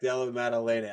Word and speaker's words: Della Maddalena: Yeah, I butchered this one earlier, and I didn't butcher it in a bad way, but we got Jack DeFella versus Randy Brown. – Jack Della Maddalena.